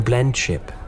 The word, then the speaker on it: blendship: blendship. – That did make me sad, Your Highness. – That's beautiful. Blemship. Am I saying it right? Blemship. – Blendship. Blendship. It's the perfect blendship.